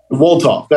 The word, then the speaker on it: Wolthoff: Wolthoff.